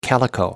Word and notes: calico: American English

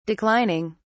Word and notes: declining: British English

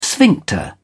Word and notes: sphincter: British English

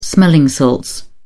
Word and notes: smelling salts: British English

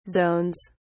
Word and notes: zones: British English

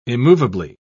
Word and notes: immovably: British English

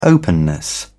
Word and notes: openness: British English

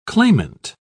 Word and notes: clamant: British English

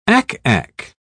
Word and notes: ack-ack: British English

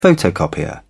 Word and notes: photocopier: British English

